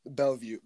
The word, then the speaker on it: Bellevue: Bellevue, Nebraska.